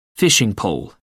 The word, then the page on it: fishing pole: British English